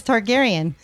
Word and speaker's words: Targaryen: Targaryen.